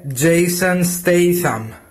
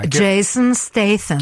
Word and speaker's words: Statham: Δεν είναι statham είναι Statham. Και μα στέλνει και μια ηχογράφηση τώρα για να ακούσουμε σωστά την, την προφορά. Γιατί Ακούστε τι είπαμε. – Η σωστή προφορά του ονόματο είναι Jason Statham.